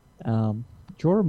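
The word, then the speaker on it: Jeor: Jorah Mormont is the lord of the Bear Islands and the son of Jor Mormont. Slightly different pronunciation.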